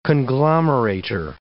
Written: conglomerator

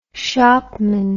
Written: shopman

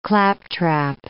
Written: claptrap

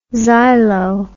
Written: xylo

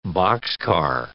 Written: boxcar

